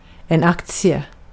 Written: hisse

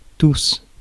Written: tous

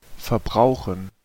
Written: consume

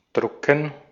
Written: print

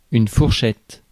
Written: fourchette

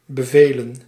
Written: bevelen

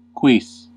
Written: anything

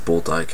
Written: bulldyke

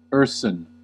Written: ursine

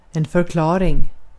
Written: förklaring

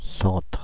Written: centre

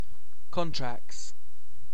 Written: contracts

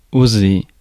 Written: hazard

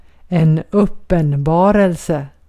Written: vision

